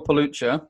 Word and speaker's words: Pelucha: I also have a very unusual surname, which is spelled P-E-L-U-C-H-A. So if you Google Phil Pelluccia, you will find lots of ways of contacting me.